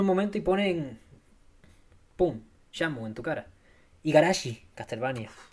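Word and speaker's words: Shenmue: Dicen que se pronuncia Shamu. eso ya me tengo creer.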